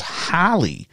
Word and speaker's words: Hailey: According to IMDb, the character's name is spelled H-A-I-L-E-Y, which we would assume would be pronounced as Haley. But in the movie, in the film, they pronounce it as Halley.